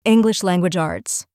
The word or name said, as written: English language arts